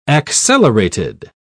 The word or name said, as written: accelerated